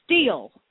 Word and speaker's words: Still: Still is pronounced steel. Steel has his nuts.